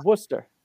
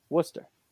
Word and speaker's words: Worcester: It's Worcester. Worcester. – I'm not saying Worcester. Then why don't you spell it Worcester? – Yeah, it's not. It doesn't look anything how it sounds.